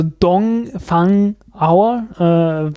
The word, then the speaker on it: Dongfang: Also wie gesagt, es gibt auf YouTube die Dongfang Hour, D-U-N-G, also Dongfang wird halt mit, das O wird gerne mal wie ein U ausgesprochen, also Dongfang Hour,